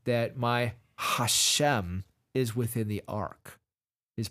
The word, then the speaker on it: Hashem: The name Hashem, by the way, is how you pronounce it in Hebrew. It's Hashem, the name Hashem.